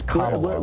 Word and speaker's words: Kamala: Really? It's Kamala, whatever. – Like Kamala.